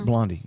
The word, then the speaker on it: Blondie: And by the way, I realize Blondie rhymes with Gandhi in case anyone texts in.